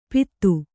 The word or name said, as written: ಪಿತ್ತು